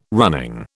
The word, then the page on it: running: British English